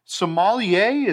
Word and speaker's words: sommelier: We're going to be sommelier.